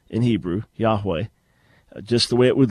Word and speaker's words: Yahweh: So it would be, and the W becomes a V, so it would be Yahweh, which sounds even stranger. But the original pronunciation, as best as we know, Yahweh.